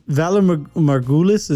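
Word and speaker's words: Valar Morghulis: So, yeah, the Vala Morghulis, is that how you say it? – Yeah.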